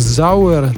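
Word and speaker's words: Sauer: На немецком она произносится как Зауэр. Также звучит и фамилия супруга Меркель, Йоахим Зауэр.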